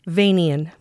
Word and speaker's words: Vanian: Did I say his name correct? – Vanian. – Vanian, okay.